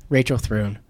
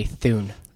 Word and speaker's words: Rachel Thrune: And creative recruiter, Rachel Thrune. – Correct. – Rachel, how many times do they say Thune? – A lot.